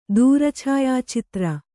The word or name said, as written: ದೂರಛಾಯಾಚಿತ್ರ